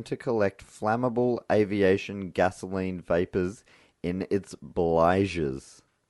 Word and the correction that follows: bilge: Bligers. – Bligs. – How do you spell that? – B-L-I-G-E-S. No, B-I-L-G-E-S. Bilge.